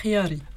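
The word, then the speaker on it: Khiary: Et donc nous recevons, alors, tu connais que je massacre à chaque fois ton nom, Sophia. Donc je vais juste dire Sophia et je vais t'essayer de prononcer... de belle manière, ton nom, mieux que moi. – Khiari.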